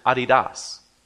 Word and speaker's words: Adidas: And if you go to Europe, they will pronounce it Adidas. We heard one of our teenagers in Ireland say Adidas, and I thought, what are you talking about? And they said, you know, the shoe company.